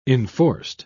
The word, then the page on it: enforced: British English